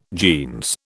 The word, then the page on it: jeans: British English